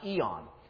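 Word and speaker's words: eon (αἰών): It's spelled in Greek with a I-O-N, and in English we spell it usually A-E-I-O-N. But it means eon, or it's pronounced eon.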